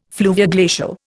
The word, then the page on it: fluvioglacial: Indian